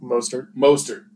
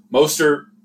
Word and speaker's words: Mostert: Not his crazy 500 yards game or anything, but Morissette had a great – not Morissette. I'm saying that wrong. – Mostert. – I can't read, I swear. Mostert.